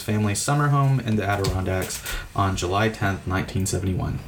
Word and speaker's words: Adirondacks: So the last story I have is in the Adirondacks. Did I say that right? – Sounded right.